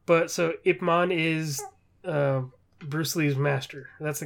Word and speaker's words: Ip Man: I definitely thought it was Ip Man. – Yeah, that's the way it's pronounced.